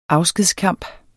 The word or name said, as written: afskedskamp